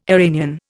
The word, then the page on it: Iranian: Indian